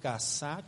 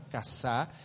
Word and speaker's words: kasá: La palabra cubrir la falta proviene del hebreo kasá con K, acento en la última A, kasá, que significa cobijar, disimular, refugiar.